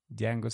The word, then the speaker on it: Django: Sí, la D no se pronuncia. Django sin cadenas.